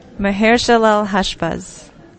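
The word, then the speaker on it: Maher-shalal-hash-baz: Mahershalal Hashbaz.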